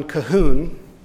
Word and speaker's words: Colquhoun: One is by John Calhoun. It's spelled Colquhoun.